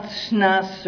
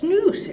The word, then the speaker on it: snus: So you have to learn that it's not snus or this or that. It's snooze. It rhymes with goose or loose.